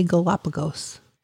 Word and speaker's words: Galapagos: How do you say Galapagos? Just like that? – Just like that.